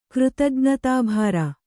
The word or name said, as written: ಕೃತಜ್ಞತಾಭಾರ